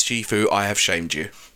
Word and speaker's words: Shifu: Shifu, which is how you're actually supposed to pronounce it. – I've heard it both ways, actually. I've heard Shifu and then Sifu as well.